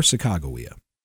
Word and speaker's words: Sacagawea: Or Sacagawea, as I would later learn it's pronounced, and here's what really happened.